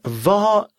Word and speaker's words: vad: Мен ва са дит ята. Но что сказала твое сердце? Следующая строчка. Ва са дин инре рост. Ва, вопросительное слово «что». Ва, обратите внимание, что буква «д» не читается.